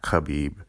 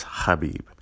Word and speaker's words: Khabib: It's not Habib. It's not Khabib. Like I just said, it's Habib. And it's not Nurmagomedov, it's Nurmagomedov. Okay, so, you know, it's like Muhammad, Nurmagomedov.